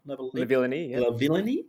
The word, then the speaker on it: Lavillenie: Lavillenie with, did I pronounce that right? – Lavillenie, 6.16.